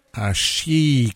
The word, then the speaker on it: Xashi: Hashi, Xashi, ça s'écrit Xashi, X-A-S-H-I par les Ukrainiennes de Onuka, extrait de l'album Collier qui vient d'arriver dans nos bacs à disques tout frais et moulus.